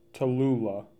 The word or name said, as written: Tallula